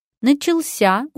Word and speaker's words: начался: Начался ударяется на последний слог, как и положено всем глаголам.